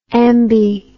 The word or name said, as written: ambi